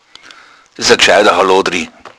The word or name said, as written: hallodri